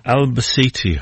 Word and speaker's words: Albacete: And Albacete, I think, is the way they pronounce the name in Spain.